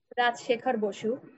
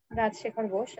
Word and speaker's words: Rajshekhar Basu: That was a Bengali translation and a summarized translation written by Rajshekhar Basu. – What's the author's name again? – Rajshekhar Basu. The Bengali name, actually, I'm pronouncing in Bengali. – Rajshekhar Basu.